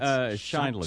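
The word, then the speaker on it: Sheindlin: I'm not even going to attempt to pronounce her last name. – It's Shindlin.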